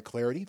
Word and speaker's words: Clardy: Clarity. The last name is spelled C-L-A-R-D-Y.